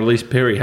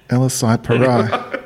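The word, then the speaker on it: Ellyse Perry: And their superstar, Elise Perry, has been performing well for them. – Oh, is that how you pronounce it? Oh, fuck. I thought it was Elisai Parai.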